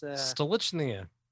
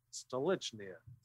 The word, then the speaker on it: Stolichnaya: That's why I'm drinking, how do you pronounce the name of this? – Stolichnaya. – Thank you. Stolichnaya.